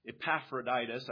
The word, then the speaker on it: Epaphroditus: One fairly well known to us, Timothy, one whose name we can't even pronounce, Epaphroditus, I think is at least the English way I would pronounce it.